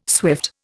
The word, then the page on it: swift: Indian